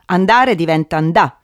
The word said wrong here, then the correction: annà: In realtà diventa annà, sempre per quella regola per cui mondo diventa monno.